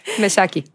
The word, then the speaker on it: Mexaki: Hay una palabra que es Meraki, que es como en griego, que significa hacer algo con amor y pasión, poniendo tu alma en ello. Y Mex, como de Meshaki.